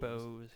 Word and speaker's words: Boaz: We also have nearby, we have Boaz, which everybody assumes is Boaz. – It's Boaz, yes. It's spelled B-O-A-Z.